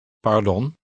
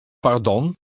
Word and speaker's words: pardon: Pardon. – Note the sound of the Dutch R in the middle of the word. It is pronounced with a slight trill.